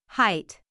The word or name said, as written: height